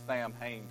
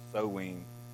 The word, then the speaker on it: Samhain: This word, it looks like Samhain to me, but it's pronounced Samhain.